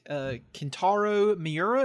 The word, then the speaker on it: Kentaro Miura: Speaking, though, of things that did die, I want to talk about the death of a man named Kentaro Miura.